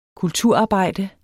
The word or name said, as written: kulturarbejde